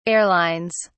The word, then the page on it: airlines: British English